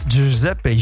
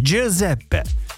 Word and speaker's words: Giuseppe: Giuseppe bildiğim kadarıyla G harfiyle yazılıyor. G ile yazılıyor. J olarak yani daha doğrusu J abi Giuseppe Giorgio Armani gibi Giorgio Armani de Giorgio okunmuyor Giorgio diye okunuyor